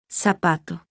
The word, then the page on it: z: Spanish